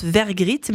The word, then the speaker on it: Vergritte: Eh bien, parce que mon nom de famille se prononce Vergritte, mais il s'écrit Vergriette, en fait, parce qu'il y a un E-T-E à la fin.